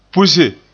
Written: puse

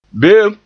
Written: be'em

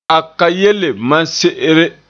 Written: se'ere

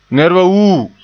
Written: wuu

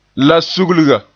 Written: sugliga

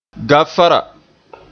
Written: gaafara

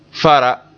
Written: fara

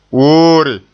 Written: woore

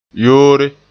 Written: yoore

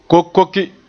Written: kokoke

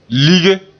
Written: lige

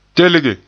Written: telge

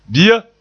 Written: bia

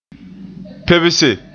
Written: pebse

